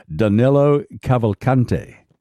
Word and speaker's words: Danilo Cavalcante: Am I pronouncing his name correctly? Danilo Cavalcante. – That sounds right.